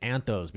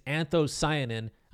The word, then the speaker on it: anthocyanin: I had to print that word out because I've been saying it wrong. I've just been calling it anthos because I wasn't sure how to say the last part because I've never had to say it out loud before, honestly, until today. But what you're seeing is anthocyanin.